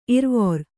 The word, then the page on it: ಇರವಾರ: kannada